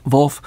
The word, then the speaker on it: Volf: How do you pronounce his last name? – Volf. Yeah.